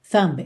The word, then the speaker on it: thumb: Tracing of my thumb, thumb, seu dedão, T-H-U-M-B, thumb, thumb. E o B não é pronunciado, você não diz thumb, thumb, é thumb, thumb, tá? Porque o M é um som bilabial e o B também, certo? Lábio com lábio.